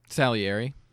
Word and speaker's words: Salieri: Salieri.